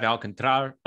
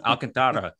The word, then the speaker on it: Alcantara: you got to get the R roll, Alcantara.